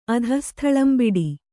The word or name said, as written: ಅಧಃಸ್ಥಳಂಬಿಡಿ